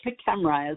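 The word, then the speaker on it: PKEMRA: So the Post-Katrina Emergency Management Reform Act, or PCEMRA as the acronym is pronounced, created the role of a disability coordinator at FEMA, which I mentioned earlier in the presentation.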